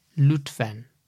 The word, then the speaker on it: Lütfen: Lütfen. – You have got a very good pronunciation. For many foreigners, saying you with dot u is very difficult.